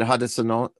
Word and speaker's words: Haudenosaunee: Haudenosaunee. – Haudenosaunee roots on your grandmother's side, on your...